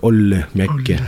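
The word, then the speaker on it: Olli Mäki: Надо именно так, Олли Мякки, да, как-то так произносить. Да,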